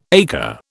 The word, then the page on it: acre: British English